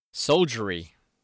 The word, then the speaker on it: soldiery: Solderry. – Soldiery. – Soul. – It's like soldier with a Y.